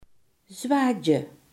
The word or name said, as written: zwakte